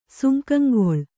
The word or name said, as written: ಸುಂಕಂಗೊಳ್